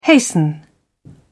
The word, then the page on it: hasten: British English